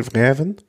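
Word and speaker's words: Fjällräven: Fjell, wie spricht man es aus? Fjell Fjellreven? Ja, genau. Äh, die was mit Specialized zusammen machen.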